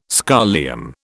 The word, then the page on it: scholium: British English